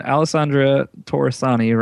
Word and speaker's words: Alessandra Torresani: If we get Katie Sackhoff, we've gotten Alessandra Torresani, right? Is that how you say her name? – Yeah. Yes.